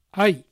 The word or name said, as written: Ai